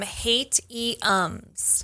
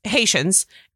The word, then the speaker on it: Haitians: She pronounces them Haitiums. – Yeah. The Haitiums. – The Haitiums. – And it's Haitians.